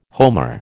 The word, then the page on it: homer: American English